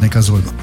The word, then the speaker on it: Neckarsulm: Also, es ist am Zusammenschluss von Neckar und Sulm. – Okay. – Und deswegen heißt die Stadt Neckarsulm.